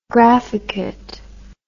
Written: graphicate

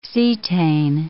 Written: cetane

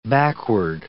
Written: backward

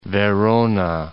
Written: Verona

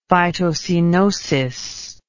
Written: phytocoenosis

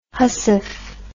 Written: hussif

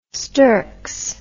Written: sturks

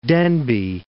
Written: Denbigh

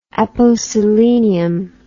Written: aposelenium

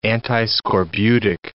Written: antiscorbutic

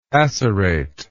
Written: acerate

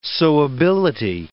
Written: sewability

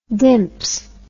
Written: dimps

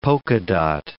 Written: polka dot

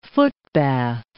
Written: footbath

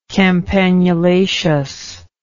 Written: campanulaceous

